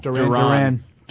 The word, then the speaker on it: Duron: Darren Harmon? No. – Duran. – How do you spell it? – D-U-R-O-N.